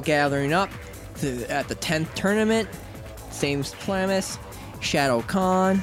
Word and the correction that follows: Shao Khan: if I remember his name, if I'm saying his name right? – Shao Khan. – Yeah, Shao Khan.